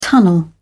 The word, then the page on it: tunnel: British English